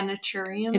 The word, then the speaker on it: Naturium: I don't know how to say it naturium. – Yeah, naturium.